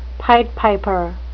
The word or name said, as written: pied piper